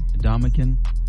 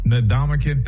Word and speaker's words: Ndamukong: Ndamukong. – Ndamukong, thank you.